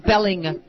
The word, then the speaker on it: Belling: Mark Belling. – Yeah. – Which rhymes with yelling.